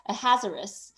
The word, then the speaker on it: Ahasuerus: in the reign of I've totally looked up how to say this name, Ahasuerus. In the reign of Ahasuerus, in his ascension year, the peoples of the land wrote an accusation against the inhabitants of Judah and Jerusalem.